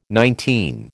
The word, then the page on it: nineteen: American English